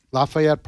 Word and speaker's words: Lafayette: Lafayette Park, that's the way it's pronounced there.